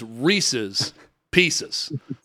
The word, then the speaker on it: Reese's Pieces: It is Reese's Pieces.